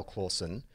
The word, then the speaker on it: Clauson: It's phonetically correct. So how do you think the Charlie bit is correct? If you were phonetically to spell Clawson, how do you think you might spell it? – C-L-A-W-S-O-N. – Close. C-L-A-U-S-O-N. He just missed one letter.